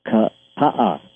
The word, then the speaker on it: Kapaa: He was, it's spelled K-A-P-A-A, and he was calling it Kapa, and the people said, no, it's Ka-A.